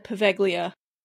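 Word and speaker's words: Poveglia: Paveglia Island. And yes, I said Paveglia because in season one, when we talked about doing this, I couldn't say it. I had to look up how to say it and continue to say it.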